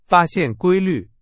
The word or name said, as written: 规律